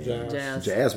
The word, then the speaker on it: Geas: Geas o Geas, come pronunciate voi? – Geas, perché è un acronimo in realtà.